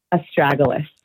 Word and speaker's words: Astragalus: Astragalus.